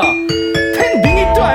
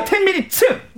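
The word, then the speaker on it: 10 Minutes: 저, 저, 이효리의 텐미니츠 아닙니까? 텐미니트 아니에요, 텐미니츠! – 와!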